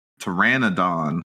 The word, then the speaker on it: Pteranodon: it's actually a dinosaur, Pteranodon. – Pteranodon. – I didn't know how to say it. There you go. – You don't pronounce the P, Joe.